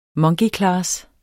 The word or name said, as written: MONKEYCLASS